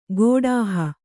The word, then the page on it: ಗೋಡಾಹ: gōḍāha